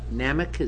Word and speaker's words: NAMIC: We have on file, in the file here, a letter from NAMIC, is that how you say it? The National Association of Mutual Insurance Companies. It says that's correct.